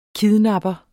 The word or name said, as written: kidnapper